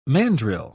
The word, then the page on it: mandril: British English